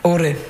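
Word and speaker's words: Åre: Ore.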